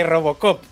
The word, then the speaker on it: Robocop: Que son los que nos han cedido tres códigos de Rogopoc. Lo he dicho bien. – ¿eh? ¿Cómo? – ¿Cómo? has dicho eso? ¿Cómo ha sido? Códigos de Robocop.